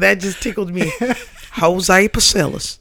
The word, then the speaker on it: Pasillas: If you're an American, and or if you're a real American, you pronounce it Pasillas. You pronounce every one of those fucking letters the way they're supposed to be pronounced.